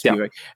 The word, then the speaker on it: Spivak: because I was thinking about Spivak, is that how you say it? – Yes, Spivak.